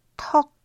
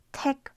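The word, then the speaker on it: 턱: Das Nomen tek steht für ein berechtigter, vernünftiger Grund und muss eigentlich korrekt ausgesprochen tok lauten. Es wird aber im Alltag oft als tek falsch ausgesprochen, wie auch in unserer Serie.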